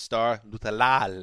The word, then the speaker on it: Star Lotulelei: And by the way, Marc, uh, I don't like to call out a colleague uh, on the pod, but it's actually pronounced. – Better not be me. Yeah, it's you. It's pronounced Star Lotulelei.